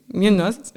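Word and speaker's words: Muñoz: José Esteban Munoz, spricht man den so aus? Queer Cruising Munoz, Utopia? Ja. Munoz, ja, okay.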